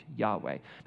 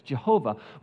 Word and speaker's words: Yahweh: When you see the word Lord, all capital letters in a lot of translations, it's referring to a divine name, Yahweh. That's how we pronounce it. We don't really know the vowels, but the consonants are YHWH, Yahweh. Maybe you've pronounced it as Jehovah. We can thank the German scholars for that one because they don't have a Y sound, so we get J, all right?